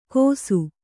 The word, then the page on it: ಕೋಸು: Kannada